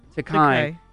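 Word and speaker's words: Takei: Takai says the same thing in his experience.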